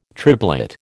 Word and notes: triblet: American English

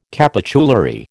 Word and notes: capitulary: American English

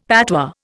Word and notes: patois: Indian

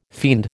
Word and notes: fiend: American English

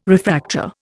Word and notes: refractor: Indian

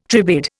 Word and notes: tribade: Indian

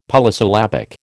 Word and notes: Polysyllabic: American English

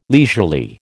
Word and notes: leisurely: American English

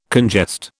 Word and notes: congest: British English

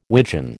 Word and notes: wigeon: American English

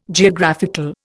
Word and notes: Geographical: Indian

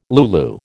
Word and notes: lulu: American English

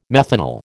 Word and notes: methanal: American English

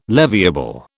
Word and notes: leviable: British English

